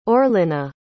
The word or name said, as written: orlena